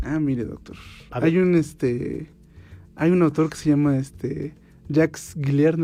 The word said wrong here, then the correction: Jacques Galinier: me parece que se pronuncia. – Jacques Galinier. – Disculpe. – Exactamente.